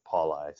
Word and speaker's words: Pauli: Yeah, I never correct anyone because, you know, phonetically it's supposed to be Pauli, right? I don't know what happened generations past, but for some reason we've always gone by Pauli.